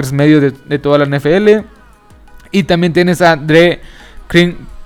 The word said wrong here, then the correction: Dre Greenlaw: perdón, a Dre Greenlaw,